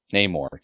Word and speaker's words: Namor: I want to say NORAD. What is his name? Namor. Jesus. – Namor. I'm like, NORAD.